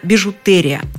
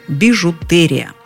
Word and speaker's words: бижутерия: Здесь нам советуют запомнить, что правильно «бижутерия». Кстати, с твердым «т» – «бижутерия».